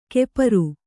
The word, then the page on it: ಕೆಪರು: Kannada